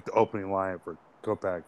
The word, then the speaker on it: Kopech: How do you say his last name? Kopech? – Yeah. – Michael Kopech over 5.5 strikeouts.